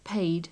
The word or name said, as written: paid